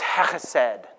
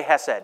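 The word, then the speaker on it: chesed (חסד): The Hebrew word for it is chesed. Go ahead, say chesed. You don't have to say it like that. Chesed.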